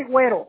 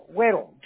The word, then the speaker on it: Güero: Just call me Güero, Güero. G-U-E-R-O, Güero.